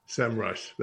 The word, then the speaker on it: SEMrush: And the founders were like, SEMrush. – SEMrush. That's it. The lord of the land came down and said, that's how you say it.